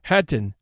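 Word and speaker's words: Khatyn: Hattin, in which the KH is pronounced like the English H, is a small village some 30 miles to the northeast of Minsk, the capital of Belarusia.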